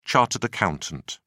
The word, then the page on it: chartered accountant: British English